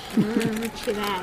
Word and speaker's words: Chile: In Chile, they spell it like chili, the country. – Which is pronounced Chile. – Chile.